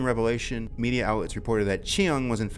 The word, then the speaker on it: Cheung: Are you sure you're pronouncing that correct? – Yeah, yeah. It's spelled like this. C-H-E-U-N-G, Chiang.